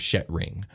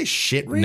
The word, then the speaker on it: Shetring: Did you say shit ring? – No, I did not. I knew you were going to say that. It's spelt Shet. Shet is an E and not I, ring. And it's all one word, Shetring.